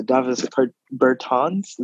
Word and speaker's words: Bertans: Same goes for Davis Bertans. Is that how you pronounce his name? – Yes, correct.